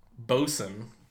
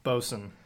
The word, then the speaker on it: boatswain: I was like, oh, there's a six-pack of Boatswain, which I later found out was called Bosun. – Bosun. But it is spelled Boatswain.